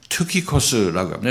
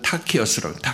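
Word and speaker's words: 투키코스 (Τυχικός): 두기고 이름이 좀 어색한데 두기고란 이 사람은 헬라 원어로 투키코스라고 합니다. 투키코스. 미국 사람들은 타키어스라고 타키어스. 그리고 같은 성경을 얘기해도 이제 미국 사람들 만나서 어떤 사람 인명 읽을 때 우리하고 전혀 다른 그런 발음을 갖고 있는 것을 알 수가 있죠. 그래서 누가 맞다 얘기보다도 두기고 우리 두기고도 좀 틀린 것 같고 투키코스가 원래 맞는 거니까 타키어스도 틀린 거죠.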